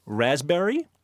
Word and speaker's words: raspberry: K-N-E-E-L, Neil. – Raspberry, answer. – Silent right. letters. – Yes, silent letters. Oh, yeah. Raspberry is? – The silent S. – P. – Well, P. Sorry. – Silent P, right.